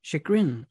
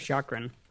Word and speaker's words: chagrin: Chagrin. Okay, yeah, I only ever read that word, so I haven't actually figured out how to pronounce it. I thought it was chakrin, but...